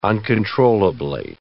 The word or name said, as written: uncontrollably